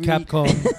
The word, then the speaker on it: Capcom: Is it pronounced Capcom or Capcom? Capcom. I've been saying Capcom, Capcom for years. – The Cap Company. It's Capcom. – The tweeter person was a failing company. – Aurich, A-U-R-I-C-H.